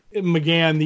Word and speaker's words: McGann: Paul Megan is going to be there. Is that how you pronounce that? – McGann, the Eighth Doctor.